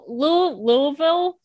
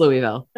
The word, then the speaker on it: Louisville: Now, Louisville. – Oh, there's a way you're supposed to pronounce it from the South, and that's not me. – Sorry, no, it's Louisville.